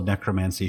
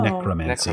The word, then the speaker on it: necromancy: syllable of necromancy should be a schwa. – Oh. – Necrom- necromancy.